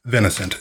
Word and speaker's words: Vince: It's like V-I-N-I. – Mm. – And I tell people, my name's Vince. And they're like, Vincent? I'm like, no. Just one syllable.